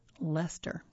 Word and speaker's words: Leicester: If you have, you're in a favorable position if anyone asks you to pronounce it. It's pronounced Leicester.